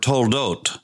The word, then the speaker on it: toldot: And it is the word, one word, toldot. Sometimes it's spelled T-O-L-D-O-T-H, toldoth.